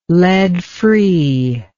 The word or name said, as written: lead free